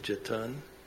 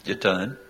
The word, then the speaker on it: Jetton: and some of us still struggle with how you say it. Jeton. – Mm-hmm. Jeton. – J-E-T-T-O-N.